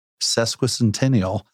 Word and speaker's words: sesquicentennial: It's our sesquicentennial.